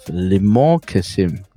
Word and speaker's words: Limor Kessem: Now today from Israel we have Limor Kessem. Did I get your name correctly, Limor? – Yes, thank you.